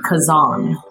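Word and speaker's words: Khazan: Kazan.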